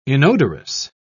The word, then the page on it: inodorous: British English